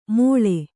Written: ಮೊಳೆ

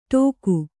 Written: ಠೋಕು

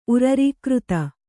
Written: ಉರರೀಕೃತ

